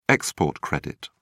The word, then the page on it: export credit: British English